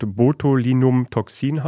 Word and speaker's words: Botulinumtoxin: Es ist ein Nervengift, das eigentlich, ich habe es nachgeguckt, Botulinumtoxin heißt, wenn ich es richtig ausgesprochen habe. – Perfekt.